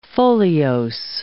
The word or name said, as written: foliose